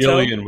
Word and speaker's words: Gillian: Gillian with a hard G. Hard G.